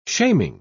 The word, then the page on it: shaming: British English